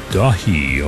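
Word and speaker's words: Daith: new album from Ilion called Dahi, although it's spelled D-A-I-T-H. I'm pretty sure it's in Gaelic.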